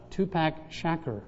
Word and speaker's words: Tupac Shakur: Well, today's Father's Day, and I was reading some words of that great theologian, Tupac Shakur.